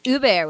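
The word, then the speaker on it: Hubert: Or is it Huppert without the H or the T? – I believe it is Huppert, right? – Yeah. You don't pronounce H's in French are not voiced, and final T's are generally not voiced either.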